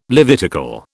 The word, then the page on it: Levitical: British English